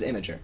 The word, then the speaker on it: Imgur: But I call it Imgur because it's Imgr. It's not, like, there's no E. – Oh. – And there's no A either. So it's like, the official pronunciation is Imgur. But I just think that's a dumb pronunciation.